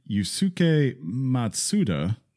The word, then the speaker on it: Yusuke Matsuda: In Square Enix's annual report, President Yusuke Matsuda, probably didn't say that right.